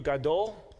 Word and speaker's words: gadol: And it's pronounced in Hebrew, gadol. Looks like that, gadol.